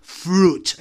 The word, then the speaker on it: fruit: Y lo mismo ocurre con fruit, que es fruta. Que se escribe fruit. La I. ¡Hala! Fuera la I.